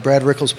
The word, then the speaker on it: Rickles: Hey, look, you know, one thing I heard in CACs, so real quick, CACs, I was listening to Brad Rickles' podcast, rhymes with pickle, remember that.